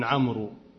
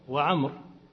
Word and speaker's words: عمرو: مثل واو عمرو لا تنطق، ذلك هذا خطأ على ألسنة الناس تقولون عمرو، هذا خطأ، وعمر مثل زيد.